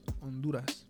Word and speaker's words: Honduras: Yeah, silent H. Honduras.